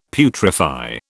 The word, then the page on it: putrefy: British English